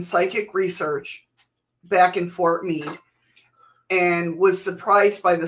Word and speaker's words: Meade: Sorry, is that what I've been pronouncing it wrong? – Yeah, it's Fort Meade. – Like, I don't know these words. – M-E-A-D, right? – M-E-A-D-E. – Yeah, Meade, Fort Meade.